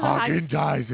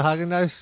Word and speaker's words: Häagen-Dazs: At the Häagen-Dazs store. And stop saying it wrong! – At the Häagen-Dazs store? Is that better? – Häagen-Dazs. – Häagen-Dazs? – Yes. – Dazs. Häagen-Dazs.